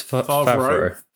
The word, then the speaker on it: Favreau: Is it Favre or is it Favre? Was it? – I thought it was Favreau. John Favreau. That's how I pronounce it.